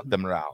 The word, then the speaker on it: Demiral: And then after that, bodies, what can you say his name? Demiral? Is that Demiral.